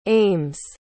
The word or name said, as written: aims